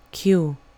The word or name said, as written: q